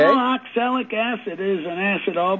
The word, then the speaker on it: oxalic: It's called oxalic acid, and it's spelled O-X-A-L-I-C. And the A-L is pronounced like in the gal's name, Sally, called oxalic acid.